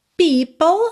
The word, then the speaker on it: people: People, atenção à pronúncia dessa palavra, que não é difícil de se pronunciar, mas não é intuitivo de acordo com a escrita, né? P-E-O-P-L-E, people, people.